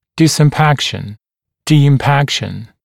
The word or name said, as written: disimpaction, de-impaction